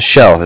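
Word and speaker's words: Schell: Shell. It's S-C-H-E-L-L. – And the company you're with? – Cardiff. You spell it C-A-R, and then D-I-F-F, like Frank.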